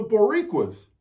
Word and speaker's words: Boricua: Or you could say it like Vince says, it's the Boricuas. Boricua.